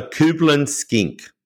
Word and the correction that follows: Cullen skink: spelled C-U-B-L-E-N, skink, S-K-I-N-K. What's this? – Cullen skink. – Oh, Cullen skink. – Cullen skink. – C-U-L-L-E-N.